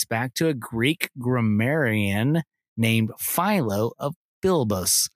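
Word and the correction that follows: Byblos: I might be saying that wrong. – Biblos. – Biblos.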